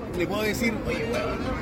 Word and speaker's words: weón: y se escribe con H. – Con W. – W, E. E-O-N. – E Ajá. Weón.